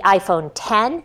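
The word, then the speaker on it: iPhone X: And when I start looking at reading between the lines of these announcements, Apple announced the iPhone 8, the iPhone 8 Plus, the iPhone 10, which is the looks like an X, but you pronounce it 10.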